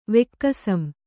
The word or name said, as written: ವೆಕ್ಕಸಂ